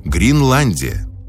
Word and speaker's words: Гринландия: Александр Грин и его «Гринландия». Произносится через букву «И», не перепутайте.